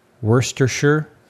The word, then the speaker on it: Worcestershire: I think the correct pronunciation is Worcestershire sauce.